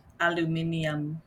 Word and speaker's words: aluminium: So this is weird, but I think in England they pronounce aluminum aluminium, and it makes me really happy. I don't like the word aluminum, but I like how they say it.